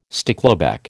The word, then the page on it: stickleback: American English